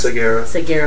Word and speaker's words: Segarra: Jorge, how do you say your last? – Segura.